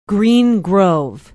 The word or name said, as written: green grove